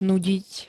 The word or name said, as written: nudiť